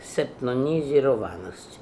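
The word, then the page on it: Chainonizironness: Russian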